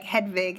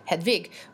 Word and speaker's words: Hedwig: So, like, some people, like, in conversation, they'll be like, it's pronounced Hedwig. And I'm like, sis, come on. – In, like, John Cameron Mitchell's interviews that I was watching in preparation for today, he was saying it with a V, and I was like, ah, man.